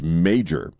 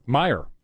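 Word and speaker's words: Meijer: I think it's pronounced Major. – Meyer. – Is it Meyer? – Meyer. Mm-hmm.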